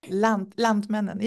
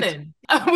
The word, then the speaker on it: Lantmännen: Lantmännen, yeah, it's right. – Lantmännen, which is a multi-billion dollar company in Northern Europe, the leader in agriculture, machinery, bioenergy, and food products.